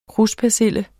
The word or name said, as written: kruspersille